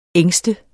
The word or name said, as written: ængste